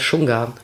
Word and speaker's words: Shunga: die heißt Shunga. – Shunga? – Ja. – Wie schreibt man das? – S-H-U-N-G-A.